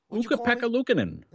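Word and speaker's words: Ukko-Pekka Luukkonen: Is that how you say his name? – Ukko-Pekka-Luukkonen. – There you go.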